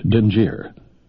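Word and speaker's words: dingir: The term was a two-syllable word, Dinjir.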